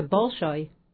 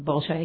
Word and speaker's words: Bolshoi: and also did a summer intensive with the Bolshoi. Am I pronouncing that right? – Yes. – Bolshoi Ballet in New York.